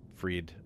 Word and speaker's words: Fried: Spelled Fried, but Fried, as far as I can tell, is the pronunciation.